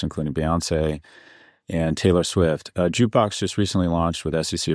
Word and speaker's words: JKBX: So fractional ownership, this company, JKBX, pronounced Jukebox, is aiming to democratize music investing.